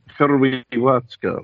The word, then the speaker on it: Hrvatska: Which, yes, how you spell Croatia in Croatian is H-R-V-A-T-S-K-A. Right, where I believe the V is pronounced almost like the Latin V as a W.